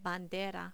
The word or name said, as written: bandera